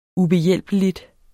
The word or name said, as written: ubehjælpelig